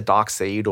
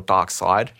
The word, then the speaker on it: Darkseid: And the character Darkseid or Darkseid, depending how you want to pronounce it. – How do you spell it? – It's S-E-I-D.